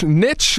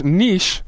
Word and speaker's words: niche: So another linguist expert, Leslie Fishopine, wrote in, says, in American English, niche is more commonly used, while in British English, niche is often preferred. In some academic or artistic contexts, the French pronunciation niche is more commonly adopted to align with the word's origin in the French language.